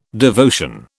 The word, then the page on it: devotion: British English